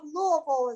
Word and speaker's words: Louisville: Or Down Syndrome of Louisville is how it's technically pronounced.